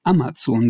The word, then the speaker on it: Amazon: Da steht tatsächlich Amazon. Man soll Amazon sagen.